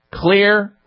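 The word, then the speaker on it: Xlear: Clear Rescue Nasal Spray. And Clear is spelled with an X, X-L-E-A-R. The X is one of the components in there, xylitol.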